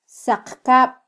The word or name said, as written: saqkab